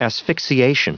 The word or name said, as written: asphyxiation